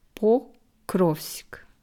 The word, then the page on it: Pokrovsk: slow